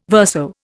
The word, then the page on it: verso: Indian